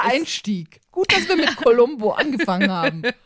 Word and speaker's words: Colombo: Genau, von Colombo zu Colombo. So spricht man es aus.